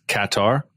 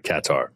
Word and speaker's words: Qatar: And hearing Peter Drury refer to it as Qatar, so emphasis on the first vowel, I will now call it Qatar.